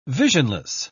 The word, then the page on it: visionless: British English